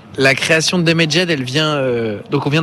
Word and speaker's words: Damaged: Alors racontez-nous un petit peu la création de Damaged, comment ça s'est passé, si je le prononce bien, j'espère. – Damaged. – Ouais, je savais.